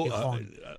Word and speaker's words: Gijón: Gijón. Lo pronuncian la J de otra manera.